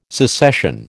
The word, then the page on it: secession: American English